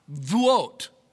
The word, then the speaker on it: Shavuot: It's Shah, S-H-A, Shah. Shavuot, Shavuot. They were celebrating the Jewish festival of Shavuot.